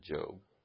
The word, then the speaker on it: Job: It looks like, but we pronounce it Job.